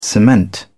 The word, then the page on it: cement: British English